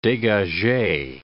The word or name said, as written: degage